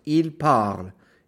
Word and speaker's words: parlent: But you completely ignore the ENT, so it's ILS, PARLENT, pronounced il parle. ENT is silent.